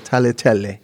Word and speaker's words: tagliatelle: Tagliatelle, which is how it's pronounced.